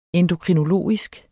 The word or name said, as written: endokrinologisk